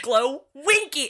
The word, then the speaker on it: Glowenke: And every time, Glowenke, emphasis on the winky.